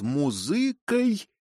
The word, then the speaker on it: музыкой: «Пьянство есть совокупление астрала нашего существа с музыкой, ударение на «ы», мироздания».